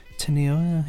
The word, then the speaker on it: Teniel: Well, it's spelt T-E-N-I-E-L, so Teniel is how I'm pronouncing it.